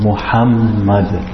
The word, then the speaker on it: Muhammad: So this ha here, you pronounce it strong. Muhammad.